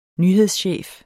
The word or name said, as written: nyhedschef